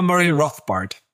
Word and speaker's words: Murray Rothbard: Weil da gehen Sie auch explizit in Ihrem Buch drauf ein, erörtern das anhand des rechtslibertären Philosophen Murray Rothbard. Ja, habe ich das richtig gesagt? – Ich kenne ihn nicht persönlich. Ich sage immer Murray Rothbard.